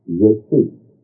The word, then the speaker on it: Iesous: But in Greek, it was written with Greek letters corresponding to our English, I-E-S-O-U-S, and pronounced Yesu. Well, take off the final S, and your Greek Yesu from the Celtic Yesu are the same thing.